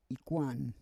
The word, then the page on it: ikwan: Roon